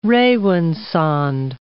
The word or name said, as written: rawinsonde